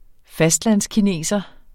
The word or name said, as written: fastlandskineser